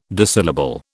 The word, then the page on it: Disyllable: British English